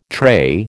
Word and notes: tray: American English

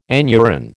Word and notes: anuran: American English

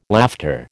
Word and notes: laughter: American English

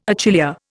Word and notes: achillea: Indian

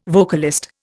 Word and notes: vocalist: Indian

